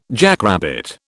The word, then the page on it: jackrabbit: British English